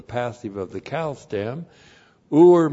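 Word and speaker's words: ur (עור): Ayin, long U, R, ur.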